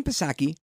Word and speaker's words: Psaki: But anyway, Jen Psaki, she says, and I know it's a silent P, that they're not going to prioritize kids or teachers because President Biden really doesn't care about that stuff.